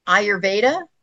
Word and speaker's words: Ayurveda: Now, I'm going to try to say this word correct. Through mindful movement, Ayurveda. Did I say that right? I did.